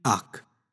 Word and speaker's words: hac: In this is very simple, in ac. Now, even though it's spelt H-A-C, we don't really emphasize the H. So in ac instead of in hac. We just cut out that extra part.